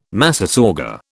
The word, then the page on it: massasauga: British English